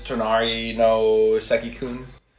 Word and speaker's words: Tonari no Seki-kun: Tonari no Seki-kun.